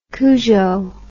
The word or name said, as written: cujo